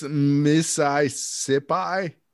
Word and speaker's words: Mississippi: This Missisipi. – Yeah. Yeah. Is that how you pronounce it? – Yeah.